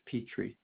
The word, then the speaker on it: Petrie: Joseph Petrie.